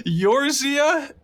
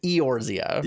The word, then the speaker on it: Eorzea: What did you think that that last noun was? Yorzia? – Yorzia is what I believe it's Eorzia. Eorzia Is how it's pronounced.